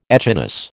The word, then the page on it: echinus: American English